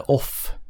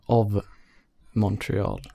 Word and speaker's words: Of Montreal: Som heter, eh, inte Off, utan Of Montreal.